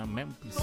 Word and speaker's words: Memphis: Saludos desde Memphis. Así se pronuncia, Memphis.